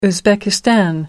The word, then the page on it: uzbekistan: American English